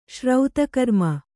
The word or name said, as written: ಶ್ರೌತಕರ್ಮ